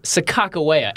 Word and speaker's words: Sacagawea: The correct pronunciation, good sirs, is Sacagawea.